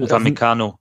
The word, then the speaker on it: Upamecano: Upamecano.